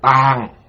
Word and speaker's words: tạng: Địa là ý nó cứng rắn, sâu dày, còn tạng cũng đọc là tàng, như nói là tàng trữ, là ý nói chứa tất cả.